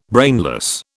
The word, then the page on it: brainless: British English